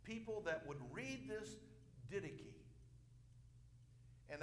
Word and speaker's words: Didache: And this book was called the Didache. Some people pronounce it the Dicky.